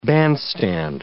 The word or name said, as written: bandstand